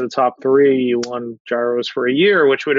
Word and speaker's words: gyros: So we're going to get into a point of contention here about the pronunciation. I'm adamant that they're called gyros, and the campaign was Arby's Greek gyro.